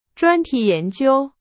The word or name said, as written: 专题